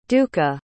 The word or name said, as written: duka